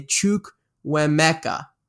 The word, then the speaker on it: Chukwuemeka: I'm going to say Chukwuemeka. I think that's pretty good pronunciation there.